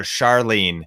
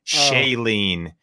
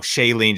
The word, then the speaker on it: Chalene: In fact, my friend Chalene Johnson, people butcher her name all the time. They call her Charlene, oh. – Chalene, Charlaling, uh, or whatever, and her name's you know Chalene Johnson.